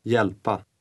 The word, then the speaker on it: hjälpa: The H is silent. – Hjälpa.